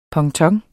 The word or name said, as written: ponton